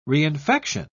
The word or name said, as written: reinfection